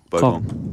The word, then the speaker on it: Bogong: Bogong. Gong, as in Wollongong.